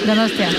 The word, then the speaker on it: Donostia: Donostia.